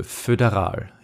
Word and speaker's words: föderal: So stelle ich mir ein Fötus vor, das ist die betonte Silbe, föderal.